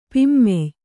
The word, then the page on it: ಪಿಮ್ಮೆಯ್: kannada